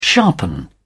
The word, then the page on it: sharpen: British English